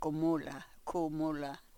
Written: ku mulah

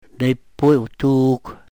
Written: nepuy we-tuuk

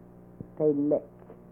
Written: telek

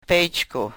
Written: pechku

